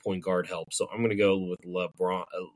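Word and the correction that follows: LaBaron Philon: How do you pronounce his name? – LeBaron. – LeBaron, sorry. LeBaron Filon.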